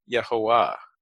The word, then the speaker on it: Yehovah: And as I'm looking at the album, it's written Jehovah, like we see in the King James, but they're pronouncing the name Yehovah. And that is a native pronunciation of the sacred yes.